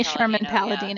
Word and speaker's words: Palladino: I've always had trouble with Amy Paladino's... I don't know. I'm not saying her name right. – Amy Sherman-Palladino.